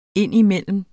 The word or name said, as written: indimellem